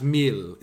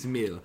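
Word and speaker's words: Mæhle: I think it's pronounced Mill. There's one pronunciation here that I've heard. It's mental, but I think it's Mill.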